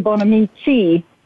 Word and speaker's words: Bonamici: Well, it's actually Bonamici. And um, hi, nice to meet you. I always tell people uh, because I'm Italian, if you use your hands, it's very easy to pronounce.